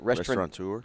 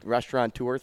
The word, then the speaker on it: restaurateur: I don't even know how to say it. – Restaurateur. – Restaurateur, thank you.